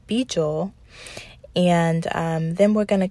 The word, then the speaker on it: Baegel: His name is Beejul, and that's spelled B-A-E-G-E-L.